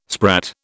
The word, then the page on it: sprat: British English